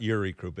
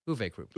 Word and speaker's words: Uwe Krupp: He said it's not Uri Krupp. It's Uwe Krupp.